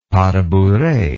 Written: pas de bourree

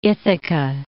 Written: Ithaca

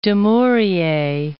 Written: Dumouriez

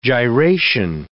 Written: gyration

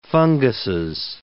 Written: funguses

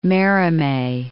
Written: Merimee

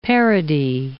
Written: parody

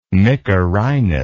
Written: mykerinos

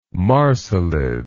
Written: Marsilid

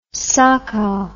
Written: sakau

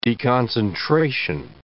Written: deconcentration

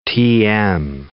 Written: tm

